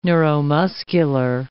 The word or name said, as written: neuromuscular